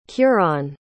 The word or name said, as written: kuron